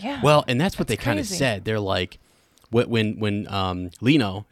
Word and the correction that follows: Kino: is that how you say his name? The leader, I forget his name. But Kino. – Kino, right? Yeah.